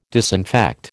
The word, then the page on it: disinfect: American English